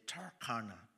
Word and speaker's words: Turkana: If you're in the States, you'll probably say Turkana, T-U-R-K-A-N-A. But let me just pronounce it the way we do it back home, Turkana.